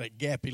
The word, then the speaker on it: agape: He says to us, I'm so in love, I've got agape love for this woman. Number one, it's pronounced agape love.